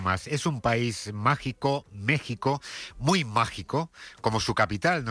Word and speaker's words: México: Ayer vimos en directo la inauguración de este Mundial en el Estadio Azteca, que recordamos porque lo vemos venir, está en México, no en México. Se escribe con X, pero se pronuncia con J, ese país que no existió hasta que llegaron los españoles, según Ayuso.